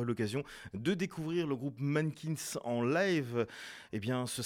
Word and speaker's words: MNNQNS: Et voilà, à l'instant, c'était le groupe rouennais Mannequins, que vous écrivez M-N-N-Q-N-S et que vous prononcez tout simplement voilà, Mannequins, sur les ondes du mix des cultures avec un extrait.